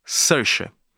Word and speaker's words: Saoirse: Sersha, que pronunciando em bom português se escreve Saoirse.